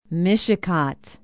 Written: mishicot

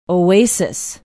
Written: oasis

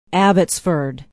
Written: abbotsford

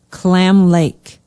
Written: Clam Lake

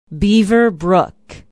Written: beaver brook

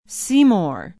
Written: seymour

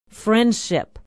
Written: friendship

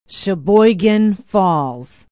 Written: sheboygan falls

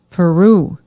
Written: peru

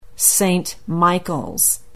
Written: Saint Michaels

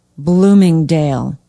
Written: bloomingdale

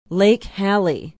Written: lake hallie

